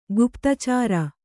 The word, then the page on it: ಗುಪ್ತಚಾರ: kannada; guptacāra